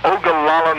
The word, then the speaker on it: Ogallala: This is Tom Shannon from Ogallala, Nebraska. I better spell it for you. O-G-A-L-L-A-L-A. Ogallala, Nebraska. Obviously an Indian name,